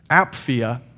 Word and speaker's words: Apphia: To Philemon, our beloved fellow worker, and Apphia, that's how you say that. It's definitely how you say it.